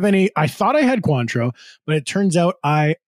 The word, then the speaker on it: Cointreau: Cointreau. Am I saying that right? – You are saying it right.